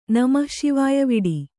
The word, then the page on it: ನಮಃಶಿವಾಯವಿಡಿ: Kannada